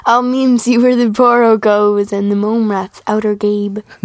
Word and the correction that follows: outgrabe: I think it's outgrabe.